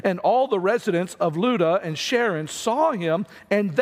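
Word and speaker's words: Lydda: He came down also to the saints who lived at Luda, which is how you pronounce that word.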